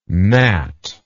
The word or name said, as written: gnatlike